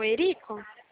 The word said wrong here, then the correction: Érico: Meu nome é Érico. – Ok, desculpa pela pronúncia. Você é o Érico?